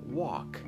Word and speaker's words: walk: در walk L قبل از K هست